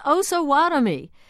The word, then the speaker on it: Osawatomie: I believe it's pronounced Osawatomie. Well, Lori Ward of Lawrence, Kansas, was one of many who wrote, "It's Osawatomie."